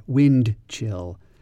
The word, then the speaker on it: wind chill: W-I-N-D-C-H-I-L-L. Now, I pronounced it in a very careful way there, wind chill. We don't usually say it like that. We usually pronounce it like wind chill. We don't really pronounce the D, wind chill.